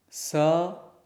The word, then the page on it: s: sanskrit